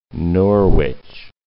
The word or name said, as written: Norwich